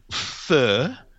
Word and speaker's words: pho: Well, that wonderful pho, as we called it, or, you know, it's spelt pho, P-H-O. But pho, a whole bowl of pho with everything in it, is £2, probably £1.80.